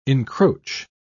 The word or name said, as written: encroach